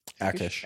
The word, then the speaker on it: Achish: Which is the same argument of, what's his name, Achish? – Akish. – Akish, I can never pronounce it right.